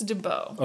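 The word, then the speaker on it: De Bow: really comes and like comes to bloom with DeBow or DeBow. – Yeah, I'm not sure how to say it. – I believe it's DeBow.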